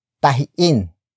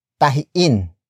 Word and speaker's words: tahiin: If the root word ends with a vowel, except for O, and it has a glottal stop, the in suffix is used instead of hin. For example, tahiin, tahiin, tahiin.